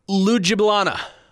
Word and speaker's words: Ljubljana: He is playing in Slovenia for, oh my God, Cedevita Olimpija Ljubljana.